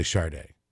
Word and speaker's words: Sade: And I had a friend, I remember in eighth grade when this was on MTV, And it's spelled S-A-D-A, right? It's really Sade. Sade.